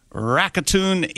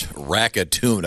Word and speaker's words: Rakuten: Put 20 dancing robots in the stands ahead of their Nippon professional baseball game against the Rakuten Eagles on July 7th. – I don't think it's Rakuten. I don't think it rhymes with Saskatoon.